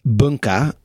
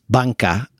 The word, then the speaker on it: Banka: So the first place we'd chosen is rather confusingly written in English as Bunka, and you could also potentially pronounce it that way in Japanese, but we think it's also meant to be pronounced Banka.